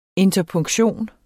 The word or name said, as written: interpunktion